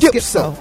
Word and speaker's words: Schizo: You put a little emphasis on Schizo.